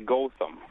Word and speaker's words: Gotham: Yeah, now, do I have the pronunciation there right? Gotham? – Our Wisconsin accent is actually Gotham.